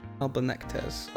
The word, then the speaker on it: Albertonectes: Albert, and then it's like Ornectes. – Albertonectes. Okay. – Albertonectes.